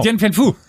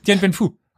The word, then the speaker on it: Dien Bien Phu: Dien Bien Phu. Dien Bien Phu.